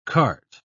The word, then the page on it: kart: British English